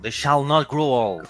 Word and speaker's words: They Shall Not Grow Old: They shall not grow old.